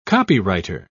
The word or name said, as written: copywriter